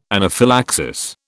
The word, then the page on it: anaphylaxis: British English